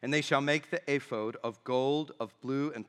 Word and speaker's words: ephod: You pronounce it however you wish, but it is pronounced ephod.